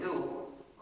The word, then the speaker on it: gnu: Se usa en muchísimos juegos de palabras porque según el diccionario la G es muda y la palabra se pronuncia GNU, como nuevo. Entonces cada vez que quieres escribir GNU, como nuevo, puedes escribirlo GNU y es un juego de...